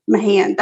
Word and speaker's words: Mahan: Sergeant Stephen Mahan, M-A-H-A-N, Mahan.